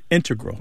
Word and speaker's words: integral: But the mathematicians and math using scientists are more likely to put, say, integral with the stress on the first syllable.